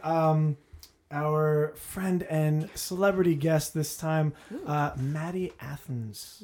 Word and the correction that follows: Anthes: Nope. – Maddie. – Try again. – Anthes. – Anthes. – Yeah, it is. – God damn. – Do it again. – I've been saying it wrong forever.